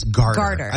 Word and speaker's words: garter: What's a gardener snake doing in Lewiston? – Is it garter or gardener? – I think it's garter. I believe it's G-A-R-T-E-R, garter snake. Okay. I've always said it wrong.